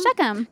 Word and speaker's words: Shechem: So he camps in, I never know how to say this one, Shechem. – Shechem.